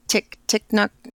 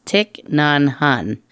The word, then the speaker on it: Thich Nhat Hanh: He's the Tick-Nock. – Oh, I'm not going to get it right. I'll have to send it to you. – The Buddhist. I think it's pronounced Thich Nhat Hanh.